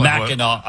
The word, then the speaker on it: Mackinac: Mackinac Island. – First of all, yeah. Yeah. – It's spelled, why do they spell it Mackinac when it's Mackinac?